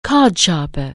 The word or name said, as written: cardsharp(er)